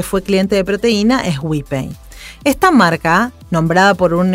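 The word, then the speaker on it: Wipei: WePay se pronuncia y se lee tal como se escribe, W-I-P-E-I, e pero ese truco de pronunciación la termina asemejando a las palabras en inglés we pay, que significa nosotros pagamos.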